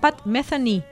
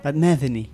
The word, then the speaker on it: Pat Metheny: Se pronuncia Pat Metheny. – Pat Metheny. – Metheny.